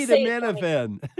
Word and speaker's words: acetaminophen: pick up a packet of ibuprofen or acetaminophen. I know I'm not pronouncing that correctly. I can't seem to get my say it for me. – Acetaminophen. – Minophen. Emphasis on the min.